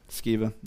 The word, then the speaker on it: Sceva: Sometimes it is, but it's talking about the sons of Sceva or Sceva. How do you say it? Sceva, yeah.